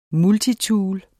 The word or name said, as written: multitool